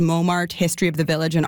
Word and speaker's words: Montmartre: So we ended up with March. So Montmartre. – Now, apparently in France, some English speakers will also drop that tricky last syllable, but they'll go with more of a Montmartre pronunciation.